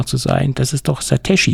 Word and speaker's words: Satechi: Ich sag mal, für mich steht da Tech am Ende, wie Technology und Satechi. Und deswegen sage ich Satechi.